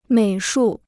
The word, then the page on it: 美术: měi shù